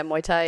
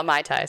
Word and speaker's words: Muay Thai: she did some research into kickboxing and Muay Thai, if I'm saying that correctly. – Yeah, Muay Thai, yeah. I used to call it Mai Tai, so I understand your...